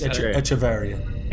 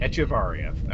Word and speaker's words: Echevarria: I believe it's Echevarria. – Echevarria, okay. Story by Christopher Teague.